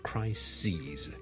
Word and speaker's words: crises: C-R-I-S-I-S 一个危机 A big crisis A number of crises okay, 留意个发音串字当然是不同的发音是不同的 crisis 和 crises 另外仲有一件事上集冇同大家讲就係 crisis 呢最尾嗰个 S 音呢係 S 嚟嘅所以个 sis 呢係短嘅而 crisis S-E-S